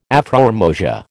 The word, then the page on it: afrormosia: American English